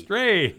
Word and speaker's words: Strahan: What's the first syllable in his last name? Michael Stray. – Stray.